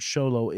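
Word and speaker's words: Xolo: Even even though the original word Sholo is a, a old indigenous word. Okay. Um, I won't go into the history. It's spelled with an X O.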